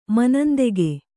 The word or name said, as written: ಮನಂದೆಗೆ